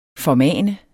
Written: formane

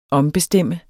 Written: OMBESTEMME

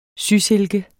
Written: sysilke

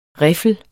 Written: riffel

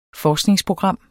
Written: forskningsprogram

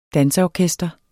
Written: danseorkester

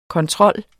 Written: ctrl

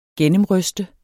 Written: gennemryste

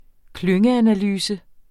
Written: klyngeanalyse

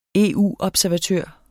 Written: EU-observatør

